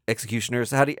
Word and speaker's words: Executioner's: everyone's geeking about Excautioner's song and, or Executioner's. – Executioner's song? – Yeah, it's spelled like Executioner. – Executioner. I always like pronounce it stupid.